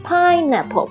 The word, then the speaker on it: pineapple: 基本上你会看到芒果，芒果的英文就是 mango, M-A-N-G-O, mango, 还有凤梨 pineapple, pineapple, P-I-N-E-A-P-P-L-E, 虽然有一个苹果在里面，但是念的时候你一定要注意这个重音是在 pine 上面，所以呢听起来 pine 比较高。 pineapple 不要念成 pineapple, pineapple, pineapple 不是 pineapple, 注意一下 pineapple。